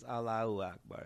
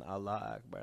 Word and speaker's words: Allahu Akbar: It's Allahu Akbar. It's not even Allah Akbar. That's not even how you say it.